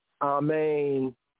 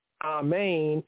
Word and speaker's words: amen: In the English, it's pronounced as an A, but in the Hebrew, Sephardic, as an ah. The Ashkenazi, the Eastern European Gomorite Yehudi, they pronounce it as an aw. The accent in both cases is on the second syllable, amen, amen.